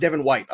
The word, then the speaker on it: Devin White: You look left, there's Devin Wright. I can't even say it right. You look right, there's Devin White.